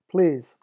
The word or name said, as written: please